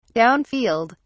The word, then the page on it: downfield: British English